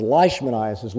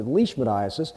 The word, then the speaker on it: leishmaniasis: This was leishmaniasis, And Leishman, a lot of people mispronounce it as leishmaniasis. Leishman was a Scot, so he pronounced it leishmaniasis.